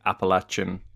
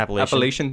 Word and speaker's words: Appalachian: And it's interesting what you say about the difference between, I guess you've got like Appalachian. Is that how you pronounce it? Everyone pronounces it differently, and I'm never sure. – Appalachian, there we go. I used to say it like that, and then someone told me it was Appalachian, so I changed it.